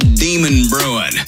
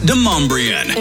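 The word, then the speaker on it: Demonbreun: Hey, just so y'all know, the street's not called Demon Brewing. It's pronounced Demumbrian.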